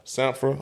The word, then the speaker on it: Sampha: Sampha.